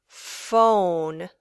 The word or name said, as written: phone